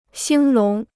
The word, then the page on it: 兴隆: xīng lóng